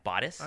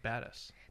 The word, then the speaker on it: Batis: So we're featuring all the Batis lenses, but more importantly. Is that how you say it? Yeah, Batis. I always say badass. They told me Batis.